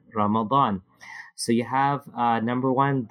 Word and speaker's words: Ramadan: Hence, they will make the Baad very distinct by saying Ramadan.